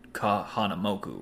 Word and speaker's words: Kahanamoku: He's like, "You mean Kahanamoku, right?" – Oh. And I was like. Oh, that's how you pronounce it.